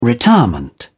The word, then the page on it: retirement: British English